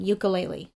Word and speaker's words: ukulele: So that's how you say it, ukulele. – Yep. – This is how it wants to come off my tongue, ukulele. – Uh-huh. – But I know that the way that whoever created the instrument or whoever feels like they have an ownership over it says that you have to pronounce it ukulele.